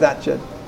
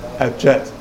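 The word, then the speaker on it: Atget: I didn't know I'd opened it Atjet. And you know that that's how you're meant to pronounce it, the French told me. – Atjet.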